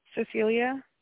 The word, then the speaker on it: Cecilia: Cecilia. – Can you spell that, please? – C-E-C-I-L-I-A.